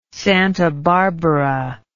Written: Santa Barbara

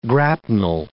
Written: grapnel

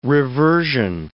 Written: reversion

